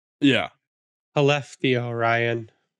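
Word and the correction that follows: Skellefteå: You don't well, pronounce the S.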